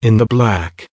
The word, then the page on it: in the black: American English